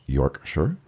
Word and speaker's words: Yorkshire: Pronounced Yorkshire.